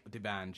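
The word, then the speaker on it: D'banj: Debanj? – Yeah, is that how you say it? – Yeah, Debanj. – Debanj. – Debanj, yeah.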